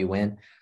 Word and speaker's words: Nguyen: It's just Joey Nguyen.